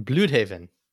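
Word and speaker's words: Blüdhaven: It's an umlaut. I'm a German double major, man. Bloodhaven.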